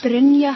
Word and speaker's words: Brynja: Brynja Haldarsdóttir. – Brynja Haldarsdóttir. – It's almost like Brynna, but with a J.